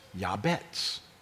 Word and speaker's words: Jabez: Phonetically for us, the transliteration is O-T-S-E-B, atzeb. But she named him Jabez, which is Yabetz. Yabetz. Which is kind of the phonetic reversal.